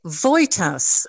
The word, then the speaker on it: Wojtas: It's spelt Wojtas, but it's pronounced Voitas, as if it was a V and a Y instead of a W and a J.